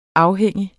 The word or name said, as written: afhængig